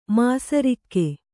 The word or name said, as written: ಮಿಸಿರಿಕೆ